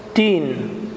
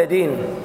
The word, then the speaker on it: الدين: وعندك الدين هناك خطأ منتشر، هو أن الدال تنطق قريبة من التاء، فيقولون مالك يوم الدين، شوف تين تين ولا دين،